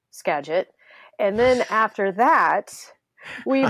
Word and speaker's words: Skagit: for value-added agriculture here in the Skagit Valley.